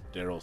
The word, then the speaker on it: Darryl: You can also find me on Twitch at Daryl Sucks at Gaming. That is Sucks spelled S-U-X, and my first name is spelled D-A-R-R-Y-L.